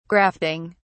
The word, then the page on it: grafting: British English